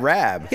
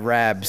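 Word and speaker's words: rabe: It was like, he's like, well, that's how it's supposed to be pronounced. I'm like, yeah, but no one ever said it right in Philadelphia. They always said Rab. – Yeah. – And my grandfather said Rab.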